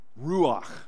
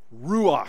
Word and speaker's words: Ruach: This is a Hebrew word, Ruach. You got to say it like you're clearing your throat a little bit. Ruach.